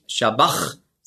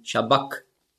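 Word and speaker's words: sabach: Und das CH im Griechischen, das ist ja ein Buchstabe, das Chi, das hat man ausgesprochen so wie ein C hinten im Gaumen. K. Also nicht Shabach, sondern Shabak.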